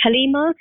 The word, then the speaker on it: Haleema: And you can follow, you know, the updates on social media at Contact Halima. So my name is spelled H-A-L-E-E-M-A.